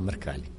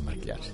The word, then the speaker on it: Mrkalj: Treba reći, dakle, pre 192 godine Sava Mrkalj, tamo izgovara i Sava Mrkljar, negdje kažu Sava, ali Sava Mrkalj je zapravo prvi jovovski zaridao u svojoj pjesmi Jao, jao, jao 300 puta.